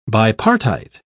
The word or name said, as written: bipartite